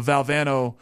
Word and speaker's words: Valvano: Well, I'm getting in the Valvano mode.